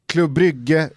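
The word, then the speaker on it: Club Brugge: Precis, det belgiska fotbollsförbundet, de hade tagit beslutet, nej skitsamma, vi gör så här att vi avslutar säsongen, Klubb Brygge vinner, de yes.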